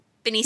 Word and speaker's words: Benicio: But we saw, how do you pronounce his name? – Benicio. – Yes, thank you.